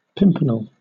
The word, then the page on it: pimpernel: British English